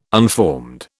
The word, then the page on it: unformed: British English